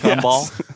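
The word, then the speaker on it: Kumble: Is Cumbel? – Let's not try. – Detective John Cumbel. – Yes. Cumbel.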